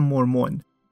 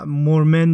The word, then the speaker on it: مورمن: این اول اپیزود باید یک چیزی رو هم اصلاح کنم، اونم در مورد تلفظ کلمه‌ای که من بارها تلفظش کردم مورمون و مورمن درسته و یکی از دوستان توی کامنت‌های کست باکس درستش رو نوشته بود و تذکر داده بود.